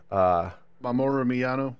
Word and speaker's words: Mamoru Miyano: And lo and behold, I'm gonna butcher his name, but Mayu Mamoru. – Mamoru Miyano? – Yes.